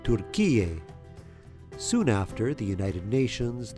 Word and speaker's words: Türkiye: With the two dots above the U, it's pronounced U, similar to the sound of U in the English word pure. Türkiye.